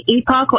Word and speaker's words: Epoch: Is it Epoch? Is that how they actually pronounce it? Epoch Times TV? – You can say Epoch or Epoch. They'll say both are fine, so it's really fine.